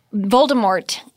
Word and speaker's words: Voldemort: But Voldemort doesn't, you don't pronounce the T apparently.